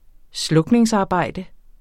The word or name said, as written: slukningsarbejde